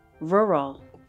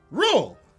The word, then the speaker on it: rural: It's two syllables. Rural. – Rule.